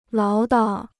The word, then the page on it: 唠叨: láo dao